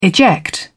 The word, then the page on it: eject: British English